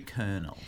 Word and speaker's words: colonel: Instead of the way it's spelt. Right. – Yes, which is C-O-L-O-N-E-L. So like colonel. – Colonel.